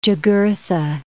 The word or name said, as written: Jugurtha